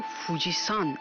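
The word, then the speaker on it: فوجیسان (富士山): ژاپنی ها این کوه رو فوجیسان تلفظ میکنند. این سان پسوند احترام که به نام افراد اضافه میشه نیست، بلکه نحوه خانش کانجی کوهه.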